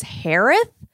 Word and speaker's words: Harith: And her last name is Harith. And for some reason, she said, uh, My last name is pronounced like Harris with a lisp.